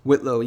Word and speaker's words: Whitlow: Whitlow, yeah.